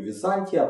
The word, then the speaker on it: Византия: Если у кого-то есть вопросы по ударению, я говорю Византия, потому что оно происходит от слова Византия или скорее правильно говорить Византион, это город. А, ну и слово это абсолютно искусственное, поэтому никакого правильного ударения там быть не может, его это слово никто в те времена не знал. Знали город Византион. Поэтому удаление на букву А.